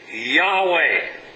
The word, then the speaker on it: Yahweh: How would you pronounce that? Yahweh. So they threw a couple of vowels in there and made it Yahweh.